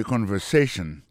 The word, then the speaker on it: conversation: Listen to the conversation. Coutez conversation. Mot conversation, accent tombé sous avant-dernier syllabe là.